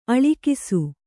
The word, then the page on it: ಅಳಕಿಸು: aḷakisu